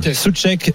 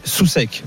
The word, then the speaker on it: Souček: Souček, Souček, voilà. – On va faire alors Souček, ouais, on ne prononce pas le U.